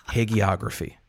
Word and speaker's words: hagiography: Hagiography. Hold on. Professor Smartass on the line here. I think you said it with a hard G. It's a soft G.